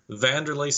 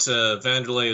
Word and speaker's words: Wanderlei: And I think he looks a lot like the MMA fighter, Vanderlei Silva. – See, now I'm going to have to Google. – Yeah, go to Google. I should note Vanderlei is spelled W-A-N-D-E-R-L-E-I.